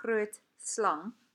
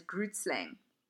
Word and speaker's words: Grootslang: And now, as you can hear how I'm pronouncing it, Grootslang. A lot of international people pronounce this creature's name as Grootslang, which I absolutely, it tickles me pink, I will say.